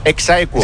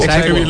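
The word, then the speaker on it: ex aequo: ¿Cómo se dice? – Exaequo. – Exaequo. – Así se dice.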